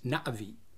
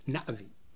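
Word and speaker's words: Na'vi: And so the correct pronunciation is Navi, where there's a sharp break after the A. It's not Navi, it's Navi.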